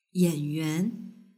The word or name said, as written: yanyuan